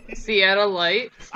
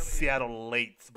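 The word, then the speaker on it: Seattleite: Seattle Lights. – I wanted to call it Seattle Lights because apparently it's Seattle Lates, but it's spelled Seattle Light.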